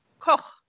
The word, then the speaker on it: Koch: Koch.